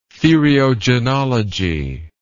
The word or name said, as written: theriogenology